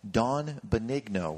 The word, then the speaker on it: Benigno: Don Benigno, with the hard G.